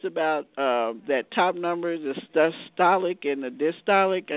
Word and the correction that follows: diastolic: It has the systolic, which is the top number, and the diastolic, which is the lower number, the bottom number.